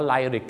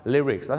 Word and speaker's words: lyrics: ไลริกกันเต็มไปหมดเลยเนื้อร้องต้องเป็นลิริกส์นั่นเองครับถึงแม้ว่าหนังนี้ออกมานานแล้วบ้านเราก็ยังออกเสียงว่าไลริกกันเยอะนะครับดังนั้นครับลิริกส์แล้